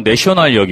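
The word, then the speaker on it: Nation: 그럼 제가 지금 구역은 잊어버렸는데 나시옹이라는 그러니까 영어 발음으로는 내셔널 역이었어요.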